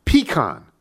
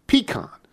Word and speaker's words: pecan: Pecan. Stress on the first syllable and a bit nasally on the second. Pecan.